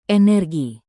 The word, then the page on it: energi: indonesian